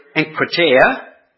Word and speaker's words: enkrateia: It comes from the Greek word enkrateia, which is spelled E-N-K-R-A-T-E-I-A.